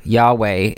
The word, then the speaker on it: Yahweh: Yahweh is the, although we're not really supposed to pronounce it, but that's the traditional pronunciation here.